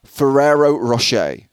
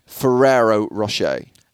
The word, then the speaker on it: Ferrero Rocher: Ferrero Rocher. Ferrero Rocher.